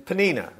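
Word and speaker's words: Peninnah: Um, the passage you just heard says he has two wives, Hannah, and then Penina is actually um, the way that they tell you to pronounce it, but I will go with whatever you want to throw.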